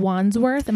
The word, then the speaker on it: Wandsworth: We're in Wandsworth.